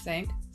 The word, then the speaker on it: cinq: Cinq? – It's cinq.